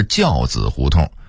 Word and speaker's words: 交子胡同: 此外还有劈柴胡同的读音应该是劈柴胡同，西河沿的读音应该是西河沿，交子胡同的读音应该是教子胡同，原来的胡同名是教子胡同，偃月胡同的读音应该是偃药胡同，白纸坊的读音应该是白纸坊，西园的读音应该是西苑。